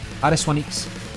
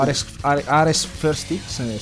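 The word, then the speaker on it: Ares I-X: Dunque, andiamo poi a vedere che Ares 1X avanza, nel senso che va di step. Tu me lo leggi, Ares 1X? – Ares 1X. – Ok. – O Ares First X suona malissimo. No.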